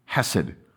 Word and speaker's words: Hesed: The pain and frustration and anger were not wiped away, but rather transformed by entering the world of this untranslatable three-letter, two-syllable word, Hesed.